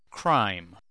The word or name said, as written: crime